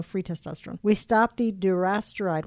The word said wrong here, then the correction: dutasteride: Am I saying that right? – Dutasteride.